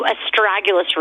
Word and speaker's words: astragalus: So I want to go into astragalus root. And by the way, people think, what is that called? It's spelled A-S-T-R-A-G-A-L-U-S. It is a root.